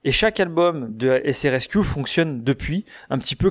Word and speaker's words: SRSQ: c'est la lettre S, la lettre R, la lettre S, la lettre Q, qui se prononce du coup SRSQ.